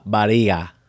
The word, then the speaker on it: barriga: I also have a Portuguese one, and it's empurrar com a barriga. How would you say that? B-A-R-R-I-G-A.